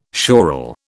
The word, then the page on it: schorl: British English